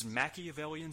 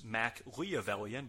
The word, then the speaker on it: Machiavellian: He grabs a mic and tells us that on September 27th, at the upcoming Breakdown pay-per-view, his Machiavellian scheme will be unfolded. Well, actually, he mispronounces it and says Mac-Leavellian, but that's okay.